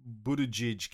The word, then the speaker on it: Buttigieg: And then the news came out quite quickly after this big failure that Pete Sodomite Buttigieg.